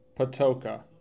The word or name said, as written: Patoka